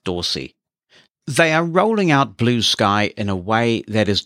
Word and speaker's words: Bluesky: One of those attempts, which has been getting a bit of attention of late, is called Blue Sky. That's how it's pronounced but it is all one word and there's no mixed case. So when your screen reader says it, it may well pronounce it as Blueski.